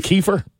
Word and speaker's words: Kiefer: Is really Kiefer. – Yeah, Kiefer. Yeah, that's. – I always said it like full Kiefer Sutherland, but when you just say Kiefer, Oh, yeah.